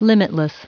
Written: limitless

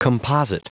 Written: composite

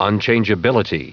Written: unchangeability

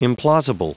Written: implausible

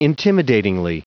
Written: intimidatingly

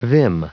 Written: vim

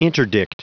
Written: interdict

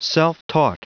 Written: self-taught

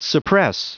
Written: suppress

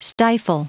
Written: stifle

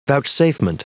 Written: vouchsafement

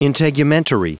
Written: integumentary

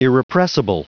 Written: irrepressible

